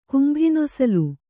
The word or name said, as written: ಕುಂಭಿನೊಸಲು